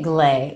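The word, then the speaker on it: GLAE: Yeah, we're calling it Glee, which is probably not the perfect pronunciation using this AE combination.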